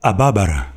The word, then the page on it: Ababara: LHLL